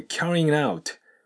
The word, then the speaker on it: carrying out: で、次のポイント、これ carrying out。これ carrying の G が発音されずに out とくっついて carrying out。そして out の T と A、これがリエゾンするとソフト D に変わる形で outer、 outer survey というふうになります。